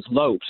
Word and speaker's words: Lopes: It wasn't actually Lopez. – He's a Hispanic guy. – Yeah, it was Lopes, L-O-P-E-S.